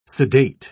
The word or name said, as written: sedate